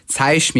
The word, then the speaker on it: zeig: Genau, das J von Zei, wie du es jetzt falsch ausgesprochen hast, das wird am Wortende eine Mischung aus CH und SCH. Also man sagt, zeig mir mal das Buch, also zeig mir mal das Buch.